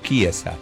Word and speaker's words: Chiesa: Italiana, Chiesa, oh, bien, se escribe Chiesa, pero como es italiano es Chiesa.